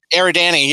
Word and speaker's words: Eridani: Uh, yeah, I don't know how to pronounce it. Uh, Eridani? – Eridani, yeah.